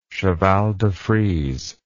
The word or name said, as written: cheval de frise